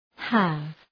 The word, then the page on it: have: hæv